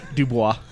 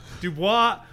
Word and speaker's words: Dubois: How do you say it? – Dubois. – Dubois. – It's French.